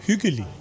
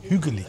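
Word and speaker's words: hyggelig: Ich habe gerade gefragt, huggelig, gemütlich, wie spricht man das aus? – Hügelig. – Hügelig.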